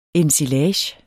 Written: ensilage

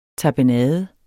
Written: tapenade

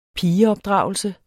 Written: pigeopdragelse

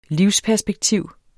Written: livsperspektiv

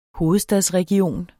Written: hovedstadsregion

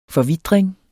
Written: forvitring

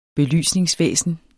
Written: belysningsvæsen

